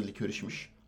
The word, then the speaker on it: Licorice: Doğru telaffuzu Licorice'miş. Az önce baktık bunu.